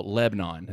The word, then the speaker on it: Lebanon: Well, Lebanon is how we pronounce it, and you know, Lebanon.